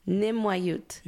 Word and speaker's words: Nimwayut: So how do you say that word again? – Nimwayut. – Nimwayut.